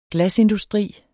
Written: glasindustri

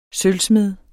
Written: sølvsmed

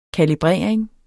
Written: kalibrering

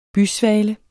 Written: bysvale

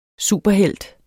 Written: superhelt

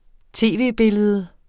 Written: tv-billede

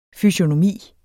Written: fysiognomi

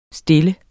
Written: stille

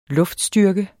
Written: luftstyrke

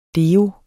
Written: deo

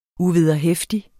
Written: uvederheftig

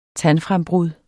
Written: tandfrembrud

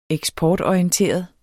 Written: EKSPORTORIENTERET

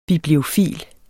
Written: bibliofil

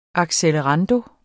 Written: accelerando

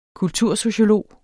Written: kultursociolog

